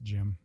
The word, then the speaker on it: Gim: And the only thing you can either recognize visually is that I never know how to say his name, if it's Gim or Jim. I assume it's probably Jim. – Yeah, I always pronounce it Jim. – Yeah, but it's spelled G-I-M.